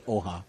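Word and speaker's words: Hoja: The H is almost silent, so it's Oha.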